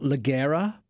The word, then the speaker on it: Leggera: It's spelt Leggera, but it's Leggera, like lingerie. And I got brought up on that, by the way. They talked to me about it. It's Leggera, not Leggera.